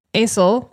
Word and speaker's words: Asle: When Andrew Helgellian disappeared, his brother, Aisle, we think is how you say it, A-S-L-E,